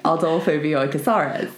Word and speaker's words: Bioy Casares: Adolfo Bioy Casares.